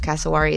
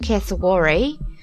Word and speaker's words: cassowary: I did research how to say this bird's name, and I believe cassowary is correct. If you put it in Australian accent, cassowary.